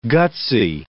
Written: gutsy

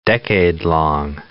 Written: decadelong

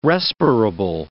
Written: respirable

